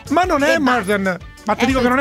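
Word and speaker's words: Marton: No, si dice Morden. Sandy Morden. – Sì, Morden. – Morton.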